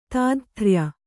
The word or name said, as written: ತಾದಥ್ರ್ಯ